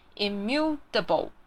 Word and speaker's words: immutable: 但是呢，在句子里面的时候，我们会把它念成 immutable，有听到那个 t 变成 d 的音吗？immutable， OK。